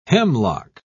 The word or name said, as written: hemlock